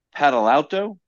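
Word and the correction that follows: Badalato: It was Bill Badalauto. Badalauto.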